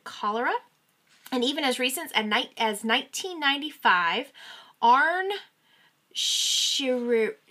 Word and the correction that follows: Shinrikyo: I know I'm saying this wrong, I'm so sorry, which means supreme truth, which was a cult. – Shinrikyo.